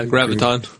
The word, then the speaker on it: Graviton: Graviton.